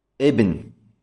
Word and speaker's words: ابن: الأول مثاله الواضح، الواضح أو ليس بواضح، لعله يكون واضحا لعله لا يكون واضحا، على كل حال فمثاله ابن، كلمة ابن. ابن إذا جئت بها في أول الكلام ماذا تقول؟ تقول ابن، ابن يعني تتلفظ بالهمزة.